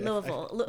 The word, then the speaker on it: Louisville: Do you have memories of Louisville? – I do. – Am I saying Louisville correctly? – Louisville. When you're from there, you say Louisville.